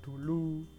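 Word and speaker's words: doeloe: Dueloe yang di, yang dibacanya dulu ya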